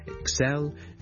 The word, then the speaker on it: excel: 個重音呢其實呢佢係應該放喺 the second syllable 嘅， so excel excel excel。